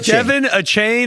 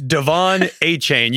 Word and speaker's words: Achane: You said Devon Achane, and it's Devon Achane. You missed both emphasis on the wrong syllable.